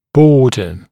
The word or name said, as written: border